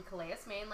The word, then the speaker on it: Calais: Andrea is an American poet and activist, originally from, I might mispronounce this, Calais, Maine? – Sure, yes, that's correct. Yep, Calais, Maine.